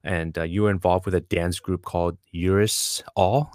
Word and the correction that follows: Uri Sawi: Is that how you pronounce it, Peter? – It's a Uri Sawi.